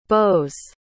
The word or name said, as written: bows